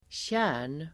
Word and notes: tjärn: [tjä:r_n]

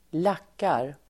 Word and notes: lackar: [²l'ak:ar]

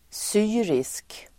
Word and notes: syrisk: [s'y:risk]

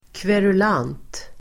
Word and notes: kverulant: [kverul'an:t]

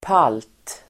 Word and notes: palt: pal:t

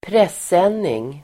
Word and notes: presenning: [pres'en:ing (el. ²pr'es:-)]

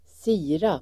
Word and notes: sirap: [²s'i:rap]

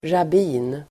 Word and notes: rabbin: [rab'i:n]

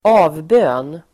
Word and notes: avbön: [²'a:vbö:n]

